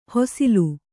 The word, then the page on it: ಹಸಲು: hasalu